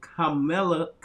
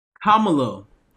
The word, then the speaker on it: Kamala: For those of you just joined, I did a calculation of 81 million, because with Vice President Kamala Kamala Harris. Why am I pronouncing? Kamala. Kamala Harris.